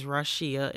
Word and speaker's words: Rasheea: Yeah, so it's available on Amazon, and the name of it is Rasheea, an Overcomer's Testimony, and it's spelled Raw-Shee-Uh, and I did that because people have such a hard time pronouncing my name. So it's Rasheea, an Overcomer's Testimony, which is available on Amazon.